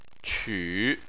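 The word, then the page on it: qu: tone 3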